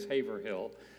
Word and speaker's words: Haverhill: We're located in the city of Haverhill, which is pronounced phonetically as Haverhill.